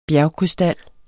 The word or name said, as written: BJERGKRYSTAL